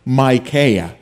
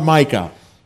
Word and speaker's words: Micaiah: He didn't like this prophet whose name was Micaiah, not Micah, Micaiah or Micaiah, however you want to pronounce him.